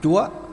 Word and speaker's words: Chúa: Là Chúa. Chứ chủ ở đây còn đọc là Chúa.